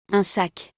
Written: Un sac

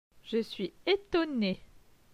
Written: Je suis etonne

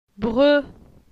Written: Bre

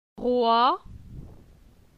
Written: Roi

